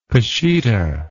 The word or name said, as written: peshitta